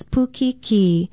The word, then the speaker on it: pukiki: Pukiki is spelled P-U-K-I-K-I with a kahako or stress mark over the last vowel sound.